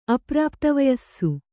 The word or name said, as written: ಅಪ್ರಾಪ್ತವಯಸ್ಸು